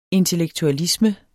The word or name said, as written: intellektualisme